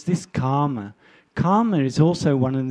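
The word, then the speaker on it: kāma: Now, this sensual desire, it's in Pali it means karma chanda. Karma, it's K long A-M-A, not K-A-R-M-A or K-A-M-M-A.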